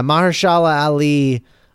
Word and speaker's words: Mahershala Ali: Mahershala, I believe. Mahershala Ali. – Yeah, Mahershala Ali, who's from Moonlight and, of course, played Cottonmouth in Luke Cage.